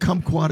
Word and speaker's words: kumquat: And it is spelled K-U-M quad, right? – Yeah. – Kumquat? – Yeah. Yeah. Kumquat sounds, it's like the country of Guam.